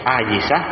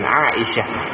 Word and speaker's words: Aisyah: Aisyah, Asiyah, dan Aisyah. Itu beda cara bacanya, beda artinya ya. Kalau nama orang Aisyah, pakai ain dulu, A panjang, Aisyah, istri Nabi sallallahu alaihi wasallam.